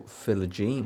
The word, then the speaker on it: Philogene: Is it Jaden Philogene or Philogene? – Philogene. – Philogene. – Philogene is way better, right? – And given away by Rob.